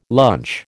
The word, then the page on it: lunch: American English